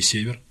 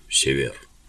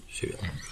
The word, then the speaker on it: Север: Потом знаменитый римский император Септимий Север, один из самых сильных римских императоров. – Извините, поправлю. Север. Север.